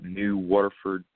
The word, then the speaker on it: New Waterford: We're planning on traveling up to, and I know I'm going to pronounce this wrong, but New Waterford Speedway. – You got it. – I believe is how you pronounce it.